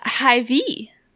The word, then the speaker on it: Hy-Vee: Hy-Vee. That's how it's pronounced on the commercials. – Like an emphasis on the V?